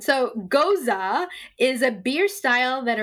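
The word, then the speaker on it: Gose: It says Ghost, pronounced Goza.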